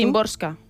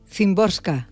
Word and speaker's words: Szymborska: Szymborska. – Szymborska.